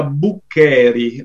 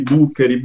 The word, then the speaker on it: Buccheri: Allora, innanzitutto ti correggo un attimo l'accento. Io mi chiamo Luca Buccheri, l'accento è sulla E e ci tengo perché non sono toscano, infatti qui in Toscana mi chiamano tutti Buccheri, Buccheri, Buccheri, perché ricorda il bucchero etrusco, il vaso funerario, diciamo, degli etruschi.